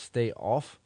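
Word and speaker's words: Steh Auf: Before that, you heard Herschlag with a, I guess the song's pronounced Stay Off. It's spelled S-T-E-H, second word A-U-F with an exclamation point.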